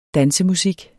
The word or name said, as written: dansemusik